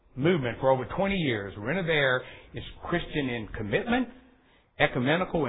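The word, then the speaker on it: Renovaré: Now, I'm going to pronounce, this is a V, I'm sorry. I pronounce it Renovare, is that correct? Renovare. It's Latin. It is Latin for to restore or to renew.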